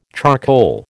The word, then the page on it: charcoal: American English